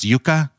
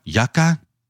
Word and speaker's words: Yuka: and so, if you want to check it out, it's just Y U K A, pronounced yucca, yucca, whatever.